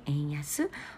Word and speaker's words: 円安: ですけれどもあとそうですね今あの円安っていう話を前にしたんですが円安 어, 지금 엔나가 약세다, 엔저다 그런 이야기를 지난번에 했는데 엔저, 엔화 약세를 보통 일본어로는 엔야스, 엔야스, 야스이って 간지요 가끔 ですね. 엔야스, 어, 싸다 뜻의 야스이, 어, 안심의 안자 한자죠.